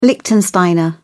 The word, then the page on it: liechtensteiner: British English